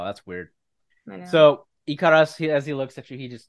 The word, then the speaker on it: Ekiras: It's like Icarus, but Japanese. Ikaris. – Ikaris, yes. – Ikarasu. – Except it's spelled E-K-I-R-A-S, like that. So Ikaris.